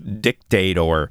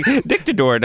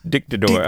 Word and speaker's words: Dictador: No, what stood out to me is that the Polish drink company's name is not pronounced Dictator. I was like, Dictador, that's slightly Dictador. Better. – It is. They put the emphasis on a different syllable.